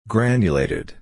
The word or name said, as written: granulated